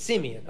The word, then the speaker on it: Simeon: It's the same name, but it's Simeon. Okay. It comes from the same Hebrew word. It's just pronounced differently. One has an extra vowel.